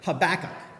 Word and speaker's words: Habakkuk: Let's say it together, Habakkuk.